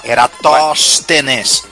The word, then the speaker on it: Eratóstenes: A era Erastótenes. – Era... Eratóstenes. – Eratóstenes, obrigado. Crivo de Eratóstenes, falei certo, olha só.